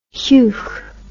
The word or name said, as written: heuch